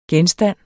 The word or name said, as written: genstand